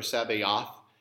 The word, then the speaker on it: Sabaoth: In fact, actually in the Hebrew, when we say, well, you've heard the word Sabaoth, right? Or Sabaoth. Sometimes people say it. It sounds like the word Sabbath. It doesn't mean that.